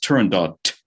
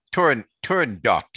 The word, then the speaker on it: Turandot: It could be pronounced either way, and either way is acceptable. But I'm on your podcast right now, and I'm telling you it must be pronounced Turandot.